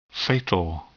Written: fatal

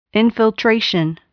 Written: infiltration

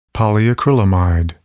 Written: polyacrylamide

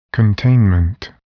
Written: containment

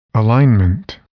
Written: alignment

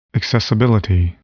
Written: accessibility

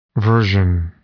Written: version

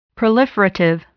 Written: proliferative

